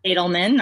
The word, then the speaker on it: Adelman: Adelman, not bad though.